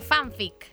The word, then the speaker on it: fanfic: Hoy traje fanfic. – ¿Fan? – Fanfic. – ¿Se escribe fun? – Se escribe F-A-N-F-I-C. – F-A-N-F-I-C.